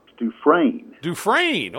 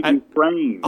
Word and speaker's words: Dufresne: Dufresne, okay. – Andy Dufresne.